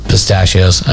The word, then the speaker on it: pistachios: I was going to say spitachios. I said pistachio. Did I say it right? No, I screwed up again. Pistachios.